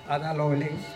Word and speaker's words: Ada Lovelace: Ada Lovelace, ¿se pronuncia así? – Sí.